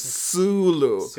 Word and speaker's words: Sulu: I know, last time, remember, I called him Zulu. – Wow, okay. – I know, and you put in my place, I know. So now I know how to pronounce his name. It's Sulu.